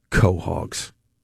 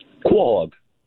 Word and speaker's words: quahog: How do you say it? – Quahog. – Quahog. Yeah, that's fine. – Exactly. – No co. – No, quahog. – Yeah, that's because there's an A.